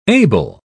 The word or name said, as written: able